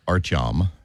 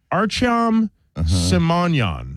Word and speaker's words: Artyom Simonyan: It's a fight between Artyomian Simoian. I am probably saying his name wrong. They're Armenian, like, kids. – Artyom. – Is that it? Artyom Simonyan.